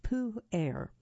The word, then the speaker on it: Pu-erh: PU-ERH. This is pronounced Pu-Air.